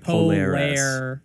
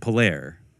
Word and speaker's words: Polaire: It's really actually, it's probably Polaris. More like Polaire. Polaire. – Yeah. Polaire. – That's how it would be pronounced. – Yeah. – Polaire. – Yeah. Fraternité Polaire. That's probably what you say. Yeah. – Exactly. Not Polaris.